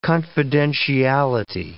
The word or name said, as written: confidentiality